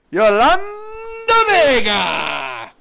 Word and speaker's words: Yolanda Vega: Yolanda Vega!